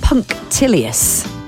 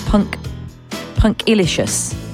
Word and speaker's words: punctilious: Is punctilious. Punctilious. P-U-N-C-T-I-L-I-O-U-S. Punctilious. I wanted to pronounce it as punctilicious. Punctilicious. But it's definitely not.